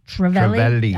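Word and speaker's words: trevally: So he's got whole fish, mullet, silver bream, silver trelly, Trevelli. I know, yep. Trevelli.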